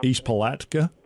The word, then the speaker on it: East Palatka: I don't know how to pronounce East Palatka.